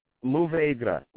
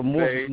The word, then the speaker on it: Mourvèdre: Mourvèdre, like moo cow, moo, or Mourvèdre.